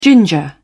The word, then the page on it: ginger: British English